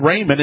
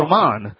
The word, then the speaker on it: Ramon: I had Raymond. It's actually Ramon. – No, it's pronounced Raymond. It just looks like that, but it is Raymond.